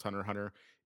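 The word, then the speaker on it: Hunter × Hunter: So Hunter Hunter stylized as Hunter X Hunter, but it's pronounced Hunter Hunter, is a Japanese manga series written and illustrated by uh, Yoshihiro, uh, Yoshihiro Togashi.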